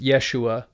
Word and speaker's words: Yeshua: And so in Jesus's time, his name was probably pronounced Yeshua, and he was probably given the name Yehoshua.